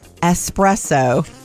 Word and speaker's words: espresso: Celebrating the traditional Italian coffee that you are supposed to drink quickly, which is why some think it's mispronounced. – Espresso. – But the real pronunciation for this deliciousness is espresso, just for the record.